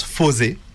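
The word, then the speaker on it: Fourze: Ça s'écrit Fours. Ça se prononce Fosé.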